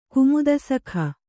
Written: ಕುಮುದಸಖ

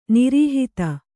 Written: ನಿರುಹಾದ

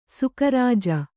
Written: ಸುಕರಾಜ

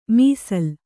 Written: ಮೀಸಲ್

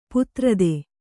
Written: ಪಥಾರ್ತ

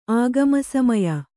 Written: ಆಗಮಸಮಯ

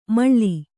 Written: ಮೇಳಿ

